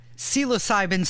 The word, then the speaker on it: psilocybin: When I asked Kev during the break before we did the segment, I said, Kev, how do you say this word? And I started spelling it. I got about three letters in, and he started shouting aggressively at me, psilocybin, psilocybin, psilocybin, idiot.